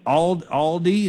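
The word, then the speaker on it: Aldie: You're in Aldi, is that how you say it? – Correct.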